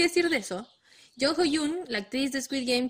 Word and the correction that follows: Jung Ho-yeon: Jung, hoy lo dije todo mal, perdón. Jung Ho-yeon. – Jung Ho-yeon. – según yo. Jung Hoyon. – Jung Ho-yeon. La actriz de Squid Game, que es como la más recordada por todos eh, los que vieron Squid Game.